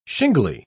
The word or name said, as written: shingly